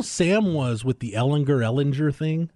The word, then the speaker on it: Ellinger: Sam was a sophomore or a junior, and I said, Sam, what's the correct pronunciation of your last name? He's like, people mispronounce it all the time. I'm like, well, it's your name. You should want the correct pronunciation. He's like, okay, it's Ellinger. I'm like, thank you.